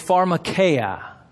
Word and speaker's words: pharmakeia: It's pharmakeia. Pharmakeia. The reason I pronounce that for you is for this very purpose.